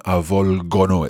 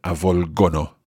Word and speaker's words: avvolgono: And also just noticing the form of it in the loro form, avvolgono, it's a hard G sound there. So avvolgere, but avvolgono, we keep the hard G.